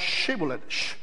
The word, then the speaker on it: Shibboleth: See, the key was that one tribe, they pronounced Shibboleth, the sh sound. And the other tribe could not pronounce it because they had not developed that sound. So instead of saying Shibboleth, they said Shibboleth.